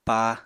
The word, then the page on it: pa: Apatani